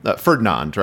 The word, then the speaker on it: Ferdinand: So I think the story, you know, has been Anton Ferdinanda came over. Ferdinand, right? We have a clarification, Bill, on pronunciation corner. – I believe so. I believe it just ends at the D, okay. like the band.